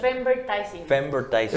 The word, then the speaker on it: Femvertising: Femver, a ¿cómo se pronuncia? Femvertising.